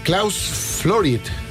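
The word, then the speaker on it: Klaus Flouride: Klaus Florid.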